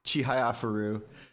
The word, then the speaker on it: Chihayafuru: Chihayafuru.